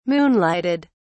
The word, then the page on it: moonlighted: British English